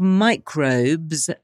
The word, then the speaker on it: Microbz: It's called Microbz and it's spelt microbe. BZ, so M I C R O, as in micro, BZ, microbes quite clever, hey?